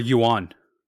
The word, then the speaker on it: yuan: Or yuan.